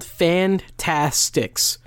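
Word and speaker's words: Fantasticks: So it's called Fantastics. – It's called The Fantastics, but it's spelled S-T-I-C-K-S.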